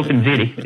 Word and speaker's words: OpenZiti: It's spelled Z-I-T-I? What is that? – OpenZiti. It is, Rick. – OpenZiti, like the spaghetti. – Yeah, it tastes good. don't Google Ziti because you'll get hungry if you haven't just eaten. But yeah, Google OpenZiti if you want to what we say,